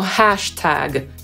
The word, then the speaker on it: hashtag: A po angielsku wymówimy to jako hashtag, czyli dwa razy występuje tam samogłoska między polskim A a E, czyli ten motylek jako symbol. No i zaakcentujemy pierwszą sylabę. Hashtag.